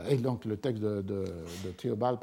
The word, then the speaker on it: Theobald: Par Mr. Theobald, je crois qu'on prononce Theobald en anglais selon Peter Stalybras,